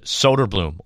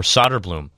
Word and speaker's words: Söderblom: You can already feel it from some of the veterans and the young guys like Arvid Söderblom or Söderblom. A lot of flexibility to how to pronounce his name.